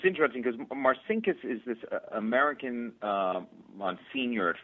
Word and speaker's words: Marcinkus: Talk to us, too, about Paul Marcinkus, if I'm pronouncing that correctly.